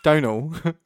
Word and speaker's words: Domhnall: Domhnall? Domhnall? Domhnall. – Donal. – Donal. That's not how you spell Donal.